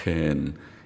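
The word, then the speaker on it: 헨 (חן): 캔, 헨이라고도 발음하고 헨보다는 크에 가까운 헨, 거친 숨소리를 내는 헨 그렇게 합니다.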